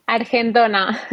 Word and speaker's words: Argentona: Es de Argentona, Argentona, eh, ¿cómo se pronuncia? – Argentona. – Argentona, vale, Argentona.